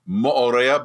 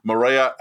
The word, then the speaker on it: Moorea: and it is pronounced by the locals as Moorea, but it's a lot easier for those of us not of Polynesian descent to say Moorea, and that's what we're going to go with.